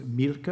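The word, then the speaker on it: Mielke: whose name was Milke, is that how you pronounce it? – Yes.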